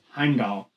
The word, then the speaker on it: Heimdall: Heimdall. It's Heimdall. – Heimdall. – Yeah, Heimdall. – Heimdall. – Heimdall. – Heimdall. You're making me think about it now. I don't know how to say it. – All right. Heimdall. Let me put it for you this way. In all of the Thor movies, they say Heimdall.